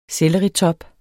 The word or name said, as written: selleritop